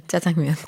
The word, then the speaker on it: 짜장면: 지금은 진짜 예, 자장면, 짜장면 다 괜찮습니다.